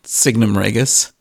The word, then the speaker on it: Signum Regis: That's how we pronounce it, but many people say Signum Regis, and I think that's fine also. It is a Latin word, so who knows how to pronounce it correctly, really, right? Because that's a dead language, so I am definitely not correcting anyone if he says Regis or Regis. It's all good for me. But you happen to pronounce it exactly the same way like we do, so nice coincidence, I guess.